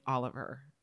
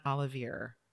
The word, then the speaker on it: Oliver: and actually confirmed for mainly Patricia and I that Oliver pronounces their name Oliver, not Olivier or Olivier.